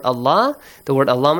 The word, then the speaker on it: Allah: So the rule says that if you have fatha or kasr or dhamma right before the word Allah must be pronounced with a heavy sound.